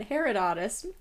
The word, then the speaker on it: Herodotus: Herodotus.